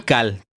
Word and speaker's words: Tikal: ¿Cómo se pronuncia? – Tikal. T-I-K-A-L, güey.